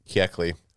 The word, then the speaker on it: Kuechly: Luke Kuechly? – Kuechly.